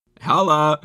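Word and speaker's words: Haula: I like that you sounded like you just said Eric Koala. – How do you say it? – Holla? Eric Holla? – Holla. Yeah, but holla indeed,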